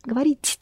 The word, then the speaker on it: говорить: Pronounce soft. – Говорить. – Говорить. Again. – Говорить. – Softer. Говорить. Говорить. – Говорить. – That's better.